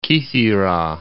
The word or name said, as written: Kithira